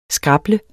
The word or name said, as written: scrabble®